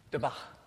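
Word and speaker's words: dābaq: It's D-A with a little hat on it. B-A-Q. Thank you. Dabach.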